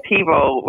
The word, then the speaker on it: TiVo: That was TiVo where you could program.